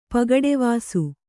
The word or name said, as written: ಪಗಡೆವಾಸು